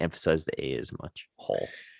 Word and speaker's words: Hall: I mean, guess they call it's him Hall. It's like Hall, but with like a short A. – Did his ancestors fail spelling? – Hole. – It's kind of like between Hall and Hole.